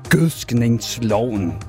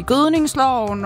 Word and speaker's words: gødskningsloven: Fordi at det kan være svært at sige, at der er nogen, der kommer til at sige gødningsloven og bare udtalt forkert, gødskningsloven. Se, der gjorde jeg det lige.